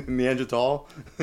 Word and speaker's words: Neanderthal: Neanderthal? – That's how you're supposed to pronounce it.